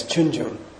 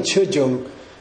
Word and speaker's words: Chunjung: And it's so heavy that you better put it as Chunjung. Because if you put Chujung, they won't know what you're saying.